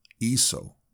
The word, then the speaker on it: Iso: She said the first syllable like the E in equal. Iso, the youth nodded to add emphasis.